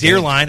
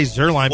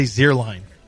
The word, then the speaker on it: Zierlein: Yeah, but mine is Zierlein, and I say Zerline because that's what people are used to. – You have the privilege of doing that. I have decided that you're going to say my name the correct way, or at least know how to say it. – When I go to Germany in a week, I'm going to say Zierlein.